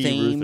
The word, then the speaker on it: Thames: They say Thames. They don't know that they're completely mispronouncing it.